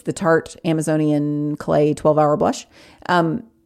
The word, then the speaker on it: Tarte: but it is Tarte makeup. Tarte is spelled T-A-R-T-E.